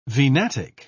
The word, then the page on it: venatic: British English